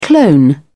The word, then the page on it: clone: British English